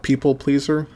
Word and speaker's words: PPLPLEASR: Pleaser DAO came together to bid on an NFT by the artist People Pleaser, spelled PPLPLEASR.